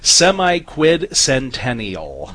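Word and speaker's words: semiquincentennial: It's a six syllable. I'll take hard words for 2000, Alex. Semi-quid-centennial. Yes. That's the word.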